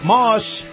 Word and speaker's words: Maus: And this portion of the program brought to you by my friends over at Moss Acura. Moss is spelled M-A-U-S.